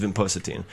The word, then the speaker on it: vinpocetine: I try that vinprocine. How do you say it? – There's, yeah. So I'm, I think I'm, I might make, this is vinpocetine.